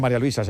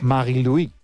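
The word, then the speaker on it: Marie-Louise: Bueno, no se dice María Luisa, se dice en su... – sí. Marie-Louis Eta, ¿no? – Así es.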